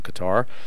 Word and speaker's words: Qatar: He went to Qatar, some people pronounce it Qatar.